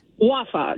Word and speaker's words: Wafa: Wafa, W-A-F, like Frank A.